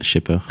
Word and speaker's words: Shaper: Tout à fait, OX shepherd Shaper.